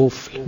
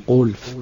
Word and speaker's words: قفل: به قفل می گفت، قلف به مبتلا گفته بود، مفتلا و امثال اون.